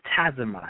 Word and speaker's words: Tazima: So let's go to Tazimal. I think I pronounced that right. Compassionate Renegade. How are you doing, my sister? – I'm feeling well. It is Tazima, emphasis on the Z.